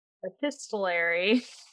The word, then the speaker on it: epistolary: This delightful epistolary.